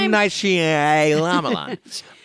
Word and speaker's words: M. Night Shyamalan: M. Night Shyamalan.